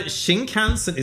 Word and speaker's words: Shinkansen: sit on a Shinkansen, is that how you pronounce it? – Yeah, the bullet train.